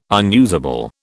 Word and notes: unusable: British English

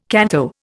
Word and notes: canto: Indian

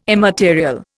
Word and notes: immaterial: Indian

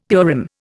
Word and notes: Purim: Indian

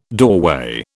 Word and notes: doorway: British English

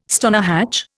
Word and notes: stonehatch: Indian

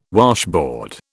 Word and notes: washboard: British English

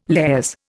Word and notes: lares: Indian

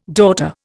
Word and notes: daughter: Indian

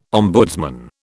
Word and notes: ombudsman: British English

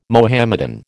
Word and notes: Mohammedan: American English

